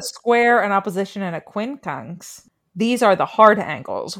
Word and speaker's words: quincunx: Quincunx? Did I say that right? – Yes. – Quincunx.